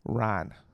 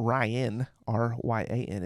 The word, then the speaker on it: Ryne: R-Y-N-E, Ryan, like Watermelon Ryan. – Watermelon, isn't that a song? – No, that's just how he told me to remember how to say his name right. Because it's not Ryan, R-Y-A-N, it's Ryan, R-Y-N-E.